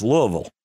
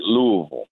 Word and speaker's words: Louisville: Now, Chris also ran a catering company up in Louisville, and it's not Louisville, people. It's Louisville. If you know. – Yeah, you say it like you've had a couple of bourbons.